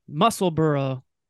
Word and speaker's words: Musselburgh: I'm saying that right again, Joe, kind of like Edinburgh, Musselburgh, Lynx it's in Scotland, right?